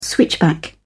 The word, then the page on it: switchback: British English